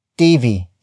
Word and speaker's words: TV: TV is spelled as T-V. It is short for television, the focus of the sentence. Note the lack of air when pronouncing the T in TV. So it's not TV, it's TV.